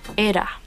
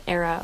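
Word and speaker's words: Eira: And the Welsh pronounce it ERA. – ERA. – But we call it ERA over here.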